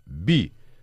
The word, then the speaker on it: 匕: Слово «би» произносится в четвертом тоне, означает «кинжал», «короткий клинок».